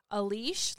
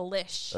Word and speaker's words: leash: My mom calls a leash, like a dog leash. Yeah. She calls it a lish. – A lish. – Go get the lish. She cannot say a leash.